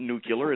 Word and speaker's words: nuclear: Everybody thinks it's cool to talk like George Bush, and it's not nuclear, it's nuclear. Nucleus. There's a cle in there, not a q, So people do that.